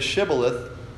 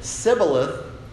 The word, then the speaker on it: Shibboleth: But if they said Shibboleth, then they let them pass through because they were Hebrews. But if they said Sibboleth, they got killed.